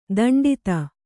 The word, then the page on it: ದಂಡಿತ: daṇḍita